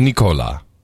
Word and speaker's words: Nicolas: Pronounced the French way, not the English way. – Nicolas.